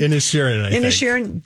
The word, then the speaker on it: Inisherin: How do you say it? – Inisherin.